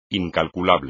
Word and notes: incalculable: espagnol

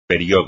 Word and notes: periodo: espagnol

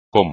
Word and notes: como: espagnol

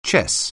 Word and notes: chess: English

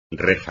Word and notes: reja: espagnol

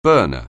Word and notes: burner: anglais

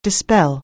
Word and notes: dispel: anglais

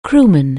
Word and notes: crewman: anglais

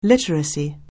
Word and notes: literacy: anglais

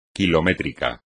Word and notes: kilométrico: Spanish

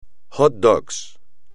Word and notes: hot dog: espagnol

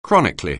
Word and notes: chronically: anglais

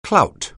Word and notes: clout: anglais

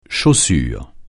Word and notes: chaussure: français

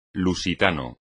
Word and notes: lusitano: Spanish

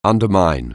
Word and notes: undermine: anglais

